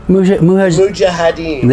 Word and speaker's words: Mujahideen: Mujahideen.